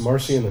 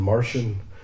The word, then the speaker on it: Marcion: Marcion, I've heard it pronounced Marcion and Martian. I kind of like Martian just because it's fun, and Valentius.